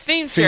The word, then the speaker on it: Feimster: My last name looks like it would be pronounced Feimster. – Right. – But it's Feimster.